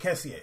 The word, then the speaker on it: Kessié: Frank Kessié.